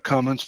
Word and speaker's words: Cummins: It's not Cummings. – Did I say Cummings? – No, you said it the right way.